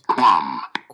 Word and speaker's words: qualm: Qualm. – How is that pronounced? – Qualm. Qualm. – Yep. You said it right.